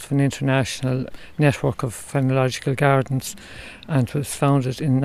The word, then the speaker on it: phenological: Fien, how do you pronounce it? – Phenological.